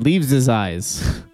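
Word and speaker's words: Leaves' Eyes: And Leaves is Eyes. I believe in English you pronounce that Leaves is Eyes. L-E-A-V-E-S with apostrophe after the S.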